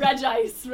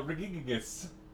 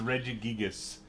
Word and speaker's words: Regigigas: What, how do you actually... It's just Regice, right? – No, Regigigas. – Oh, Jesus, that's how it's actually pronounced? – That's, it's Regigigas. – Okay, but that's not any better.